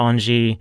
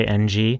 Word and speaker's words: Engie: Yeah, maybe it'll be helpful to talk about Engie and Catherine. I'm so glad that you brought in the right pronunciation. I always say NG.